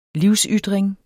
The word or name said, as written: livsytring